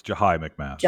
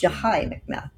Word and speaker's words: Jahi McMath: And And the story of is that how it's pronounced? Jahi McMath? – It's Jahi McMath. – Jahi McMath, thank you.